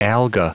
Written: alga

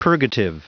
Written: purgative

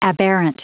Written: aberrant

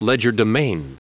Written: legerdemain

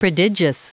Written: prodigious

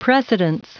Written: precedence

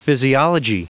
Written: physiology

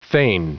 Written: feign

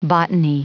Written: botany

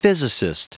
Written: physicist

